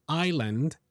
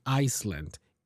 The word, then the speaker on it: island: For example, the word island has a silent S in the middle, even though it's not pronounced. We don't say Iceland. If you were to pronounce the S, it would probably sound like the country, Iceland.